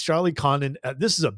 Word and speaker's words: Condon: I've heard his name pronounced two ways, Condon and Condone. I'm saying Condon. – Condon. – Right? Condon. I've heard Condone, but I love Condon.